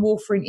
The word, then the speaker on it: warfarin: David asks, do you have an alternative to, I hope I say this right, warfarin? – Warfarin.